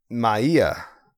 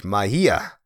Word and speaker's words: Magia: Do you pronounce the G's? I don't think you do. I think it's like an H. Magia, which is Spanish for magic, okay?